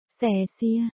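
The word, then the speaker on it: xè xia (谢谢): Chữ cảm ơn trong tiếng Trung phát âm là xè xia.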